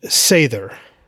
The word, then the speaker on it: Seidr: I mentioned Seidr, which is spelled S E I D R. And the D in Old Norse is kind of like a, a blend of the D sound and the the sound. It's really tough to say.